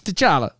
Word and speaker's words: T'Challa: T'Challa.